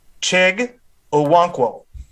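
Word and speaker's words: Chig Okonkwo: At 95, we've got the Bengals taking, can you please pronounce his name, the tight end from Maryland? – Chig Owonkwo. – Chig Owonkwo, there we go.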